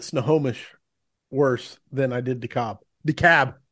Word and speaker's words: DeKalb: It's DeKalb, not DeKalb or DeKalb or any other pronunciation. It's DeKalb, like why call a cab when you can call DeKalb? – DeKalb.